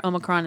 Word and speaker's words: Omicron: Because in America, it's Omicron or Omicron, and that's fine. Okay. The Greek pronunciation puts the emphasis on the me, on the middle part, and it says Omicron.